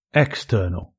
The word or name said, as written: external